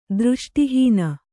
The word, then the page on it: ದೃಷ್ಟಿಹೀನೆ: dřṣṭi hīne